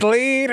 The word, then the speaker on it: Lede: But it rhymes with Lede. It's Lede. Her name is Lede. L-E-A-D, fool.